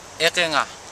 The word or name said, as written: ekenga